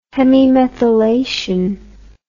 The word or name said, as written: hemimethylation